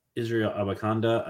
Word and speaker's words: Israel Abanikanda: All right, I'm up, and I'm gonna go with Israel Abanikanda.